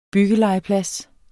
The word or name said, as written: byggelegeplads